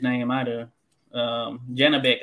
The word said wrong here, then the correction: Janibek: I think, is his first name. So typically had to go by that. Janabek. I can't pronounce the last one. – Janibek Alim Kanuli.